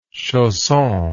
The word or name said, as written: chausson